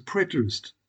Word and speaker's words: preterist: Firstly, there is the preterist, some people pronounce it preterist, school.